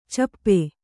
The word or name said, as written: ಛಪ್ಪೆ